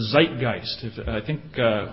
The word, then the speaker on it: Zeitgeist: The Germans refer to that as the Zeitgeist. Horst, you correct me if I haven't pronounced that correctly. Is that okay? Okay, thank you.